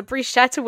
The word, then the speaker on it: bruschetta: Oh wait, actually, I'm going to talk about this starter I had in Venice, bruschetta.